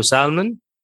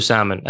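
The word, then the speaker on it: salmon: بس هل يمكن اللغات تانية او لهجات تانية مثلا بريطانيين يقولوا له سالمن يمكن ما اعرف، بس انا اعرف انه الامريكان انا ثقافة امريكية يقولوا سامن الال ما تتنطق.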